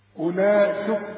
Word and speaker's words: أولئك: الواو عليها الدوران خمسة مدورة. كل ما تلاقي حرف عليه دوران ما تنطقوش، يلغى في النطق، يكتب ولا ينطق. وبيسمها حروف علة. كم حرف من حروف العلة دول في القرآن؟ ثلاثة، الواو والياء والألف. فإذا لقيت حرف عليه خمسة تلغيه من النطق. أولئك اشبك الهمزة مع اللام على طول.